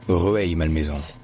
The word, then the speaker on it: Rueil-Malmaison: Parce que, regardez bien, en fait, ça ne s'écrit pas R-E-U-I-L, ça s'écrit R-U-E-I-L. Donc, il n'y a pas de EU qui fait E. C'est Rueil. Rueil-Malmaison. Rueil-Malmaison. Rueil-Malmaison.